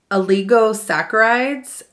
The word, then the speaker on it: oligosaccharides: There's, hopefully I say this right, there's oligosaccharides and polysaccharides.